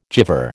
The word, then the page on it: gyver: American English